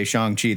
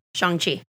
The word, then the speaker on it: Shang-Chi: And we're going to both try to say Shang-Chi the whole time, because that's how you say it. – Shang-Chi.